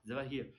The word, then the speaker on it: Zavahir: Zavahir.